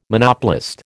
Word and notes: Monopolist: American English

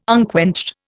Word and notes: unquenched: Indian

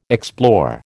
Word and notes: explore: American English

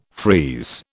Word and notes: freeze: British English

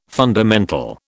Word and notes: fundamental: British English

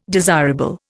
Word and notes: desirable: Indian

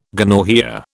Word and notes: gonorrhoea: British English

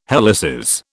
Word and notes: helices: British English

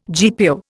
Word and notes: GPO: Indian

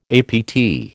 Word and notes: APT: American English

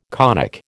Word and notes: conic: American English

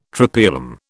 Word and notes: Tropaeolum: British English

